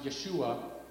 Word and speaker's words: Yeshua: What's interesting is Joshua is actually pronounced Yeshua, and in Hebrew, it's a shortened version of the name Yehoshua.